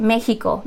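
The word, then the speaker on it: México: So for example, Mexico in Spanish is Mexico. The X is more of a H sound.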